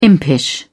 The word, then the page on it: impish: British English